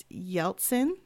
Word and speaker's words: Yeltsin: You also had a song, Our Generation, serving as the anthem in President Boris Yeltsin.